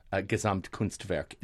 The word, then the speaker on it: Gesamtkunstwerk: And it's also fabulous and fun and a real Gesamtkunstwerk, dare I say that? – Yeah, I think you just did. Yeah, okay. Very nicely pronounced as well.